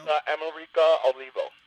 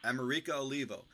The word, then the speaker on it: America Olivo: I got to tell you, we also have to talk about your actress, America's Olivia, or how does she pronounce that? Do you know? – America Olivo. – America Olivo.